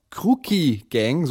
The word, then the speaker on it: Crucchi Gang: Die Kruki Gang, so spricht man sie aus, die macht Germano-Italo-Pop.